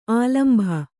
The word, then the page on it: ಆಲಂಭ: ālambha